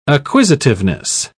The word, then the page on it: acquisitiveness: British English